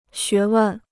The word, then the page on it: 学问: xué wèn